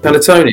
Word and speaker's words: panettone: I didn't spell it right, but was it panalone, the cake bread panalone? Is that how you say it? That's what. – Panettone, isn't it? – Yeah, panettone.